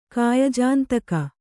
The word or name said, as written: ಕಾಯಜಾಂತಕ